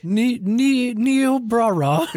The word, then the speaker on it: Niobrara: Niobrara.